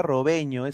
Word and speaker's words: Robegno: A ver, dice Pineda, Robegno se pronuncia Robeño, es apellido italiano.